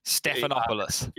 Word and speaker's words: Stephanopoulos: Stephanopoulos.